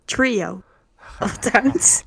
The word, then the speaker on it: Trio of Towns: Zeg ik het goed? – Tree of Towns. – Hoe? – Trio of Towns.